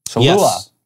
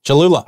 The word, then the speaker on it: Cholula: So they go, Cholula. – Cholula. – I can never say it right, you guys. It's fine. – See, I know. I used to leave that other L out, too.